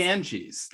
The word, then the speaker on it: Ganges: The Ganges? Is that how it's pronounced? The Ganges.